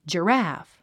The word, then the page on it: giraffe: dʒərǽf; ジラフ